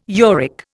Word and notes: uric: Indian

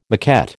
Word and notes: maquette: American English

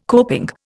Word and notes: coping: Indian

